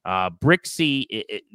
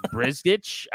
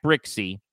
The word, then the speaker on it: Brzykcy: Brixey, that's how you say his name. It's spelled B-R-Z-Y-K-C-Y. So it looks like Brizgich. I don't even know how you would pronounce that based on what it looks like. But it's Zach Brixey.